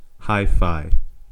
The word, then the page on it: hi-fi: /ˈhaɪˌfaɪ/